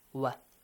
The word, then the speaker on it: و: So, first one, wa. – Yes. – Wow. Okay, it's not v, wa.